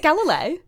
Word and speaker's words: Galilei: Is it Galilei? – Yeah.